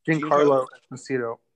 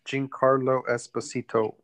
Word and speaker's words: Giancarlo Esposito: Giancarlo Esposito. – Giancarlo? – Mm-hmm. – Yeah. Giancarlo Esposito.